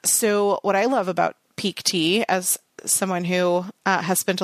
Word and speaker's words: Pique Tea: But before we jump into all of those, I do want to thank our sponsor for this week's show, Peak Tea. And that's spelled Q-U-E. So P-I-Q-U-E-T-E-A.